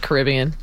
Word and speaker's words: Caribbean: Or Caribbean. – Yes. – Everyone says Caribbean when they reference this. – No, I say Caribbean. – Yeah. Which is, of course, the correct pronunciation.